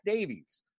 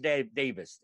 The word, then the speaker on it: Davies: So I'm just used to like, okay, Zach Davies. But it can be pronounced Davis.